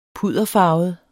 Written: pudderfarvet

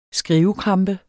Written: skrivekrampe

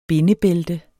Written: bindebælte

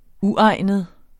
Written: uegnet

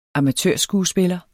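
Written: amatørskuespiller

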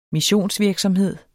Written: missionsvirksomhed

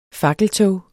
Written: fakkeltog

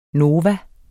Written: nova

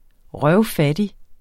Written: røvfattig

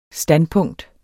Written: standpunkt

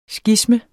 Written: sgisme

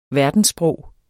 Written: verdenssprog

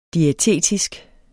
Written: diætetisk

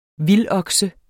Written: vildokse

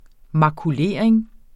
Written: makulering

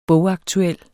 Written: bogaktuel

